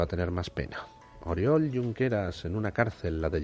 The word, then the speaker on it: Junqueras: Oriol Junqueras, pues resulta, perdón, Junqueras, se pronuncia Junqueras, lo siento, siempre se me olvida.